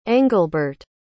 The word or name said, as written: englebert